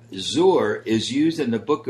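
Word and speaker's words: Zor (זר): And very interestingly, Junie, the word for strange in Hebrew is a word that's pronounced Zor. Perhaps if we were to spell it phonetically, it would be Z O O R.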